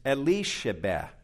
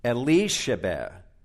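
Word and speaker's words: Elisheba: The Hebrew form of the name Elizabeth is Elishabeth. Elishabeth. Or think Eli Sheba.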